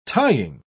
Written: tying

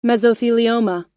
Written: mesothelioma

